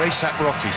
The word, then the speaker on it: A$AP Rocky: I think that's A.K.A. Rocky, or A$AP Rocky, sorry.